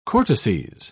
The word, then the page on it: cortices: British English